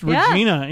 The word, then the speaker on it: Regina: Did you know that it was originally, I read something the other day, it was originally supposed to be pronounced Regina. Yeah.